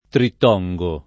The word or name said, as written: trittongo